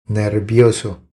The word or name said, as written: Nervioso